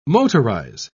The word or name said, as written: motorize